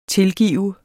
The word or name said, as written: tilgive